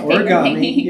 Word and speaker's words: origami: They do jewelry making, origami, I think is how you say that. – Yeah, origami.